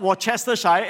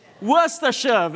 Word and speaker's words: Worcestershire: Well, it's not Worcestershire. It's Worcestershire.